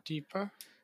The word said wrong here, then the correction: Jotapata: No, what is it? How is it spelled? – It's Jotapata. Jotapata.